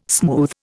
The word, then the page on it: smooth: Indian